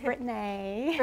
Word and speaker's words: Brittnay: And my name is spelled a little differently. It's Brittnay.